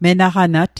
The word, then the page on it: menaqanat: Mi'gmaq